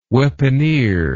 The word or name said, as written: weaponeer